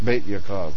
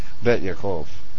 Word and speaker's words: Beit Yaakov: Beit Yaakov. There you go. I pronounce as bait. Kind of reminds me of fishing. Beit Yaakov.